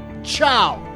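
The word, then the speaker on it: ciao: Kiayo, trivia heads. I'm Joe's mom's neighbor, and the... What? You pronounce that chow. Chow.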